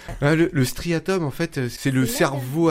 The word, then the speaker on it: striatum: Le striatum.